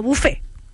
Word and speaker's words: buffet: Hay que decir que, claro, que viene del francés buffet, es WFET, y se pronuncia como bufé, buffet. Y en español, bufé, sin la WF y sin la T sería, ¿no?